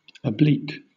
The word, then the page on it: oblique: British English